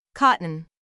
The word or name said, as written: cotton